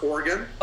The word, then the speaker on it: Oregon: Yeah, Josh, I really appreciate it, and it's pronounced Oregon. – Oh, I always say that wrong. I actually went to the Oregon PHCC, and they made fun of me. They're like, it's Oregon. Like, what are you doing? – Thanks for that correction.